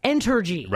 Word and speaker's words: Entergy: Entergy. Right. – Sounds like energy, but it's Entergy. And depending on the seasonal load, the owner says that Indian Point supplies between 18 and 30% of downstate energy needs, right?